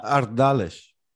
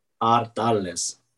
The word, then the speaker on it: Ardales: Ardales. – Ardales. Did I get that right? – Yeah, very well. – Perfect. Well, this weekend there is actually the Fiesta de la Matanza de Ardales, which is, if you don't know, a incredible celebration of traditional sausages and other pork products that are produced in the area.